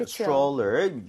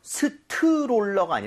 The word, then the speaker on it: stroller: 읽을 때 여기 TR, mom 이렇게 되면 얘가 T하고 R을 충돌을 하면서 치읓 비슷해집니다. 그래서 스트롤러가 아니라 입 모양을 좀 모으면서 stroller 이렇게 해서 발음을 해보는 거예요. 두 번 갑니다.